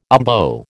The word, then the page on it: umbo: American English